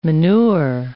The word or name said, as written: manure